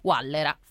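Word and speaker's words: guallera: Wallera, femminile singolare. Ma intanto la pronuncia è corretta? – Sì, è proprio questa.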